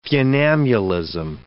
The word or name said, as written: funambulism